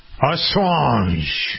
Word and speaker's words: Assange: Assange.